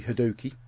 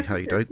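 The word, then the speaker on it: Hadoke: a patron of the show and patron and friend of the show, Mr. Toby Hadoki. It's actually Haydo, but people like to pronounce it wrong.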